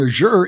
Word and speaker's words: Azure: And Azure is A-Z-U-R-E. I had to look up how to pronounce it, so it is Azure.